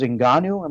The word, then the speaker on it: Ngannou: Arlovski versus Ngannou, am I saying his name right? – Yeah.